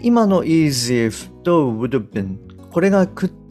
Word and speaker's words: would have been: なので、まあ、適当にこう弱く発音されます。で、結果的にどういうふうな音になるかっていうと、 would have been, would have been。はい、こんな感じで発音されます。